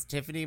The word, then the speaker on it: Tyffanie: My name is Tiffany Morgan, and that's spelled T-Y-F-F-A-N-I-E.